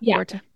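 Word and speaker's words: Borte: Even, is it Borta? Am I saying her name right? – Yep. – Borta.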